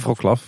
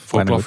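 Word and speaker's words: Wrocław: Maar toen we ook duidelijk hadden dat we naar Berlijn gingen en dat we dus naar uh, zeg ik het goed? Ja, Vroklav. Vroklav gingen.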